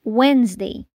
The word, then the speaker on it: Wednesday: Letra D. A palavra mais comum em que a gente encontra a letra D silenciosa é Wednesday, quarta-feira.